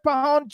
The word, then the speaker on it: Pajon: You said Pajon or something. – No, the J is silent. – Pajon. Okay, hold on, everybody.